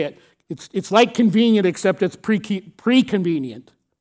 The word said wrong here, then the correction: prevenient: Grace. I'm not saying it right. I screwed it up. Prevenient grace. There I got it.